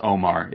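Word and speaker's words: Oumar: This comes to us from, I believe it's just pronounced Omar. It's O-U-M-A-R.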